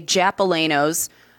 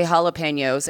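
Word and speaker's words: jalapeño: Chuck's also the reason I say Japalanos. I never say jalapenos. And when I say Japalanos, I always get a look. And I'm like, I know it's pronounced jalapeno.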